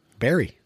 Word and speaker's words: bury: How do you pronounce B-U-R-Y? – Barry.